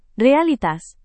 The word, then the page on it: realitas: Indonesian